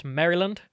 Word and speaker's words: Maryland: FYI, it's pronounced Maryland. No pause in between.